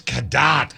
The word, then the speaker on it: Cadott: I mispronounced it too, but Jason, it's Cadott.